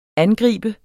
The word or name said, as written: angriber